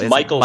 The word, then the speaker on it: Michael: Yeah, it's Michael Sliwa.